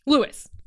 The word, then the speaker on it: Louis: like his name is spelled L-O-U-I-S, and I always think that you don't pronounce the S when you're saying Louis, but it's Louis. Louis. Louis, not Louie.